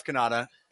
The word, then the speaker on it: Cannata: I am, uh, you know, I am Jeff Cannata, spelled with two N's and one T.